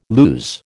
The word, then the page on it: lose: American English